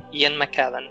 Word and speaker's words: Ian McKellen: A gente tem o Ian McKellen como é que fala o nome dele? McKillen, né? McKillen. – Ian McKellen.